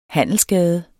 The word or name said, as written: handelsgade